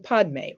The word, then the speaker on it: Padme: So that leaves two other words, Mani and Padme, or Mani and Peme, as it's pronounced in Tibetan.